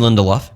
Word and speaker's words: Lindelof: They had some big names involved, like Damon Lindelof. How do you say his name? – Damon Lindelof. Yeah.